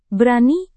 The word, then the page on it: berani: indonesian